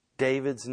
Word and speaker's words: David: In Hebrew, it's pronounced David. If you were to transliterate that and spell it into English, it would be D-A-V-E-E-D. But we spell it D-A-V-I-D, but it's pronounced in Hebrew David, and it literally means beloved.